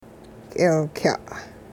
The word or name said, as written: k'eelkya'